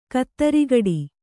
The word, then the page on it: ಖುದ್ರಗೇಡಿ: khudragēḍI